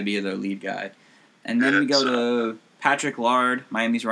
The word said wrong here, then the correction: Laird: I feel like that's not how you pronounce Patrick Lard. Laird's name. Laird's, whatever.